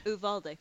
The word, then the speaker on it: Uvalde: Our reporters, went behind the scenes at the top secret And how do you pronounce it? Uvalde? – Uvalde. – Uvalde test facility in, I guess, is it New Mexico or Texas? – No, it's San Antonio, right.